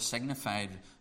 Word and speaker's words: signified: That word signified really means given in signs and symbols. It should really be pronounced signified, because that's what it means.